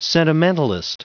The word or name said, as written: sentimentalist